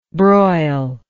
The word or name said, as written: broilingly